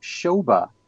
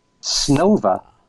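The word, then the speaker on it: Snova (Снова): There is Paul's Russian album, which for many years I pronounced Shoba B-C-C-C-P. But Alan has told me that the correct pronunciation in Russian is Snova. Snova. Okay. So I'll have to say it correctly from now on. Snova B-C-C-C-P.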